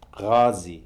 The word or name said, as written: kazi